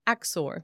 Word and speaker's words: Axor: Axor.